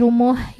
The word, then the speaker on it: rumoh: Dalam bahasa Indonesia kata rumah, tapi dalam bahasa Aceh rumah itu diucapkan dengan bunyi rumoh gitu.